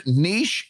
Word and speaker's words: niche: I've actually looked it up, and niche and niche are both acceptable pronunciations.